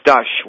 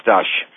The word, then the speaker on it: Stash: It's Stush, which is, it's spelled S-T-A-S-H. If we were really spelling it the right Polish way, there'd be a little cup above the A, but it's pronounced Stush.